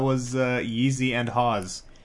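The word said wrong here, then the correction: Hov: Am I saying that right? – Yeezy and Hove.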